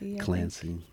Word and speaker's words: Clancy: Clancy.